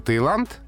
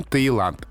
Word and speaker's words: Таиланд: В названии страны Таиланд пишется буква «и», но очень часто там звучит «йод» и пишется буква «и» кратко ошибочно. Наверное, смущает слово «тайский». – Мы это обсуждали, да, как-то. Да, угу. то есть там Таиланд.